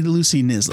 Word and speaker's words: Knisley: Probably Lucy Knisley. – Probably silent K. – Yeah. Like the P in pterodactyl.